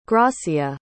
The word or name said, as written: gracia